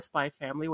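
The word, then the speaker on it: Spy × Family: I also watched all of Spy X Family or Spy Family, whatever it's called. – I usually keep the X silent when it's in the middle. – Yeah, I'm told that the X is silent.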